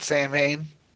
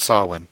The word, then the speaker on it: Samhain: Some people call it Samhain, but it's technically pronounced Samhain.